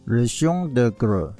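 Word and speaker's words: Le Chant des Griolles: 后来我当然知道这个叫做保罗加布列底下的一支叫做 Vaness Ruchon de Grès，是这样发音吗？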